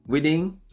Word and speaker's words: Widing: We're going to say it right. Whiting? – That's good. That's good enough. Yeah. – Good enough. Mr. Whiting on Instagram, but it's spelled like Whiting.